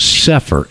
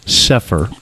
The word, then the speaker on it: Sepehr: You can go on YouTube, and he's got a channel, And his name is Robert Sefer, S-E-P-E-H-R is the way it's spelled, Sefer.